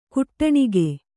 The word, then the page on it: ಕಟ್ಟಣಕ: kaṭṭaṇaka